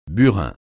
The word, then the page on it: burin: francais